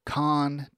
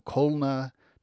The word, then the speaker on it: Calne: So the town is C-A-L-N-E in Wilshire. I've seen people attesting that they are lifelong local residents, each offering up radically different variants from Con to Con to Colna to Colney to Carn and Carney,